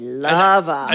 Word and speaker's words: Luva: Now, and Lash Lova, can you spell it? – L-A-S-H. L-U-V-A. – Oh, L-U-V-A. Mm-hmm. Okay, Lova.